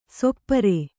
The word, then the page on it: ಸಪೂರ: sapūra